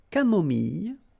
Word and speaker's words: camomille: So remember when we get this E and then double L, it goes like Y, Y. Mille, mille, camomille, une camomille, un thé au lait, final T not pronounced, un thé au citron, un thé glacé.